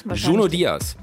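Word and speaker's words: Junot Díaz: Oder spricht man den dann Junot aus? – Junot Diaz.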